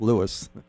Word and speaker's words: Lewes: And then there's something also L-E-W-E-S, which we want to say Lewis, but it's Lewis.